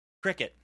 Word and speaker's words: Cricut: paper flowers with the use of my cricut. Cricket? How do I say that? Cricket. All right, so it's it's the word cricket.